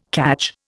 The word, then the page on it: cadge: Indian